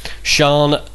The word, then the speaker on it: Sian: The first one was the murder of Sian... is it pronounced... – Sian O'Callaghan.